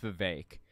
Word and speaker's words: Vivek: And he talked about the fact that Vivek Ramaswamy, I'm sorry, his name's pronounced Vivek.